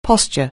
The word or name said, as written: posture